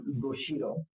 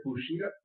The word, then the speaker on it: Bushido: Bushido. Did I pronounce that correctly? – Yeah, sure. – Bushido. – Like you're boo and you're like hot Cheeto, you're Bushido.